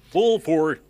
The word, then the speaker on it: Burford: Burford o Barford, como me diga que se pronuncia. – Burford.